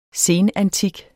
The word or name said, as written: senantik